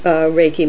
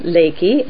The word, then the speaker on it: Reiki: Reiki, as they call it, kind of with an L pronounced instead of an R, like as Westerners pronounce it.